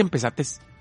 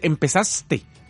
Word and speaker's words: empezaste: No, no se dice empezates. Se dice empezaste.